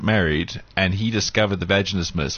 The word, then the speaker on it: vaginismus: Yeah, but this couple's going through this whole vaginismus thing, and I'm sorry, did I say that right? – Yes, you did.